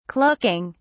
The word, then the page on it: clucking: British English